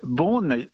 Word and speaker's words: Beaune: Well, Beaune, you said it very well.